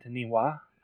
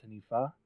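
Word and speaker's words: Taniwha: Taniwa, I think is actually pronounced Tanifa.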